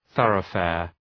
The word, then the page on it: thoroughfare: ‘ɵʌrə,feər